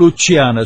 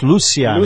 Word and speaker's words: Luciana: It's not Luciana, it's Luciana in Spanish.